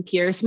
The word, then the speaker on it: Pearce: Both are at Jackson Pierce. My name is spelled P-E-A-R-C-E, which many people get wrong.